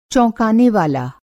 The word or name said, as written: चौंकानेवाला